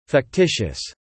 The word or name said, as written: fictitious